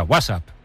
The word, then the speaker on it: WhatsApp: Dilo peor aún, con acento en la primera. WhatsApp, ¿no? – Un WhatsApp.